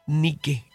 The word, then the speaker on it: Nike: Nike, acento en la E. Como Nike.